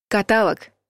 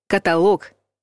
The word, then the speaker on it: каталог: Каталог — неправильный вариант. Каталог — правильный вариант. Век каталогов, косметики и одежды практически закончился.